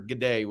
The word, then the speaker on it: g'day: And is teaching me the proper g'day, which I am not quite there yet, but I'm getting there. – It's got to flow. G'day. It's just one word. It's not two syllables.